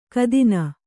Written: ಘಾತಿನಿ

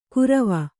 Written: ಕುರವ

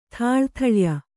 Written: ಥಾಳಥಳ್ಯ

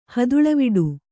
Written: ಹದುಳವಿಡು

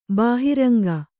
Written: ಬಹಿರಂಗ

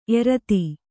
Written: ಏರ್ತೆ

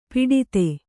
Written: ಪುಟಿತ

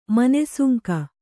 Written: ಮನೆಸುಂಕ

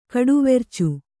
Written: ಕಡುವೆರ್ಚು

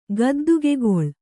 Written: ಗದ್ದಿಗೆಕಲ್ಲು

